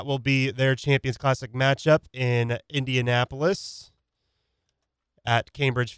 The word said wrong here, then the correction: Gainbridge: Fieldhouse, like I said. Gainbridge Fieldhouse, my bad. I pronounced that wrong.